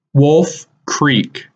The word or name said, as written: Wolf Creek